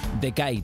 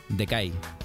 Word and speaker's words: Decai: Decay, tal cual, ¿eh? Decay. D-E-C-A-I se escribe.